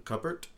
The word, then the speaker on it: cupboard: Cupboard? – That's not right. – Cupboard. – He's pronouncing the P in cupboard. He's like... – There's no P, though.